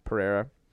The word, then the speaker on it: Pereira: Pereira. Kevin Pereira. – Yeah, Pereira.